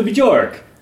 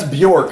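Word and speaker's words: Björk: I'm like, oh, cool, a new Bjork. I know I'm saying that wrong. – That's Bjork for all of you listening at home.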